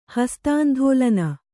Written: ಹಸ್ತಾಂಧೋಲನ

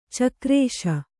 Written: ಚಕಾರಿಸು

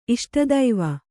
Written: ಇಷ್ಟದೈವ

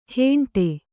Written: ಹೆಂಡೆ

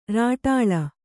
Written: ಱಾಟಳ

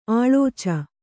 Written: ಆಳೋಚ

